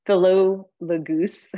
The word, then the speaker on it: Philologus: Greet Asyncritus, Phlegon, Hermes, Patrobus, Hermas, and the brothers who are with them, Greet Philogus, nope, I said that wrong, Philologus,